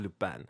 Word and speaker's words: Lupin: I'm not going to imitate a French accent, but yes, in the show, it's pronounced Lupin.